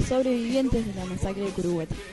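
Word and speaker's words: Curuguaty: Se dicta sentencia sobre la masacre de Caraguatí, así se pronuncia, Curuguatí. Tiene problemas con el guaraní.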